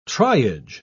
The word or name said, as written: triage